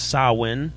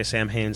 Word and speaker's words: Samhain: Is that it's interesting because Samhain is a Celtic word. It's technically pronounced Samhain. – Really? – Yeah, and I see it, and I can't not say Samhain. So we know we're doing it wrong, but we're gonna keep doing it wrong.